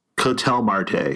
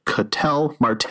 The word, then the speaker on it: Ketel Marte: Ketel Marte, because they actually have the pronunciation right here on the website. Ketel Marte. They even tell you which syllable to emphasize.